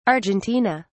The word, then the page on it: Argentina: British English